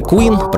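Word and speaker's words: Qween: И напоследок сегодняшнего знакомства с Ретро Стефсон еще один сингл — это песня Queen, произносится как королева, но пишется через W, изданная также в виде отдельной 7-дюймовой виниловой пластинки.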